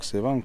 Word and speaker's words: Sevan: c'est bien prononcé, le lac Sevan.